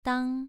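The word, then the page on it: dang: tone 2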